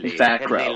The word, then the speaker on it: Fackrell: I want to say I heard somebody professional as fuck say Fack Row.